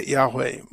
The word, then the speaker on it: Yahweh: but the usual pronunciation is Yahweh, but we don't know what the pronunciation is because they wouldn't even put the vowels for the name of God in the Masoretic text of the scripture, the Hebrew Bible. But most would say Yahweh, which is related to the word for creation.